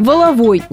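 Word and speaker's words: валовой: После всех трансформаций в русском языке сложилась традиция ударения на последний слог. Так что правильный вариант здесь всего один – «воловой» ударение на последний слог. В этом сходятся все словари без исключения. Причем вариант с ударением на первый слог приводится как ошибочный.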